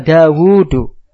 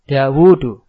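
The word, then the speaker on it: Dawudu (داود): Nah, sama juga Dawudu. Kalau bacanya Dawud salah. Dawudu. Karena di situ ada wawu yang tidak ditulis.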